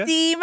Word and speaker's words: Tim: Hoe zouden zij zijn naam uitspreken? Team Held. – Ja, ik denk precies zo. – Team Held.